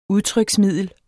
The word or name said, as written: udtryksmiddel